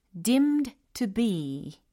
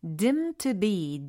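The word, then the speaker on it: deemed to be: dimmed to be 이렇게 pp형으로 어, 적혀 있는데 얘를 발음을 할 때는 dim to be 마치 없는 것처럼 이렇게 자연스럽게 연음 처리가 되니까 참고해 주시면 좋겠어요.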